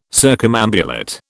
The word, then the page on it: circumambulate: British English